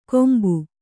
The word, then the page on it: ಕೊಂಬು: kombu